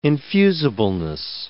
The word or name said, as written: infusibleness